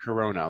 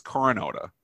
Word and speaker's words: corona: Corona, like the beer. – Coronavirus. – Well, it's not the corona. It's not. It's exactly said corona, like the beer. I thought it was coronota or something.